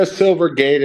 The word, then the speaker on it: Silvergate: Is it, did I say it right? Silvergate? – Yeah, Silvergate. It's SI.